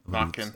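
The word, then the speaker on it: Naquin: Sell high right now on Nakin. – Is that how it's pronounced? – Knockin. It's knockin. – It's French.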